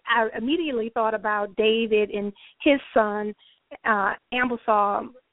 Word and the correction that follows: Absalom: and if I'm saying the other name, Absalom. – Absalom. – Thank you, Misi.